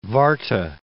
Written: Warta

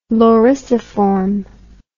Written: lorisiform

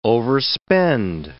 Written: overspend

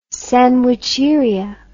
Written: sandwicheria

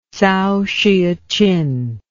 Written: tsao hsueh chin

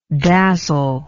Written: dazzlement

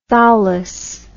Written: thowless

